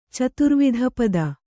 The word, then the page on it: ಚತುರ್ವಿಧಪದ: caturvidha pada